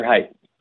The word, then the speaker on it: Haight: I don't know if it's pronounced Haight or Haight. I hope it's Hunter Haight, because that's a great name. But do you know how to pronounce it correctly? – Hunter Haight. – Oh, it is Haight.